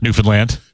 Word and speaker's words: Newfoundland: Is that how you pronounce it? Newfoundland? – Newfoundland.